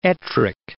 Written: Ettrick Forest

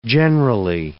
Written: generally

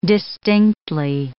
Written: distinctly

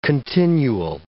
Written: continual

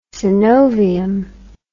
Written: synovium

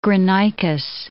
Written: Granicus

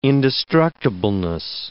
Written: indestructibleness

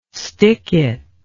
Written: stickit

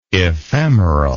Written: ephemeralness

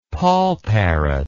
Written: poll parrot

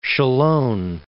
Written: Cholon

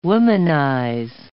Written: womanize

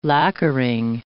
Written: lacquering

